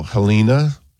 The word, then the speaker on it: Jelena: And then witch hazel, Helena, or Jelena. Mm-hmm. It's spelled Jelena, but I... – it is. I think you're right in pronouncing it that way.